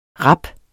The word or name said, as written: rap